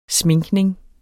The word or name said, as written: sminkning